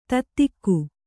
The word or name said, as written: ತತ್ತಿಕ್ಕು